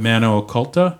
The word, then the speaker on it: Mano Occulta: If you're listening to this before August 18th, 2023, please come to our return show at Mano Occulta. Am I saying that right? – Yeah, that's right.